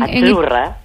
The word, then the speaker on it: azzurra: La zeta no es la zeta como decimos en catalán tan sonora, no. – Ah, es azzurra.